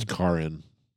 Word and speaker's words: Caryn: Oh, look how it's spelled though. Caryn. – Karin. – It's Karin. Karin.